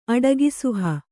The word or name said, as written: ಅಡಗಿಸುಹ